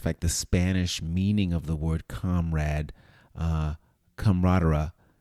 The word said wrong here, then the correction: camarada: I'm saying it wrong, camarada, which it means chambermate.